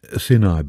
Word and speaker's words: Sinai: Sinai, by the way, is the correct pronunciation.